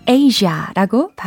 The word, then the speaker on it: Asia: 네, 특히 아시아가 아니라 Asia라고 발음을 해야 된다라는 것도 챙기시면 좋겠어요.